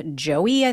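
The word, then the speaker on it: Joei: with his wife, Joey, I think is how you say her name. It's J-O-E-I.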